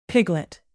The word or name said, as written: piglet